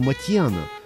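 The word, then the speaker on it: Motian: В первые годы 49 минут джаза я произносил его фамилию как Моушен. Потом великий знаток джаза, живущий нынче в Бостоне, Сабирджан Курмаев, написал мне, что Пол армянских кровей и что его фамилия и звучит по-армянски Матьян.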